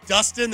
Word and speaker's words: Dustin: Because every time I say my name's Dustin, they're like, Justin?